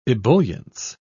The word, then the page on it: ebullience: British English